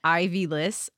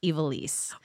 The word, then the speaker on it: Evilise: Her handle is at, it looks like Ivy Liss, Ivylis, but her name is pronounced Evilise.